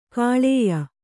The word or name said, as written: ಕಾಳೇಯ